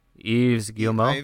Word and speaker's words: Yves Guillemot: Yves Guillemot.